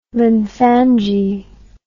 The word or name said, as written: lymphangi